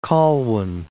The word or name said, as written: Colwyn Bay